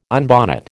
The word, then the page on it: unbonnet: American English